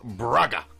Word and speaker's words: Braga: And following that, we're going to see Freiburg versus Braga.